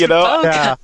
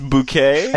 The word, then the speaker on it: bokeh: So is it pronounced bouquet or bokeh? – Bouquet. – I don't think it's bouquet. – You know, no. I saw it both ways. – It's bokeh.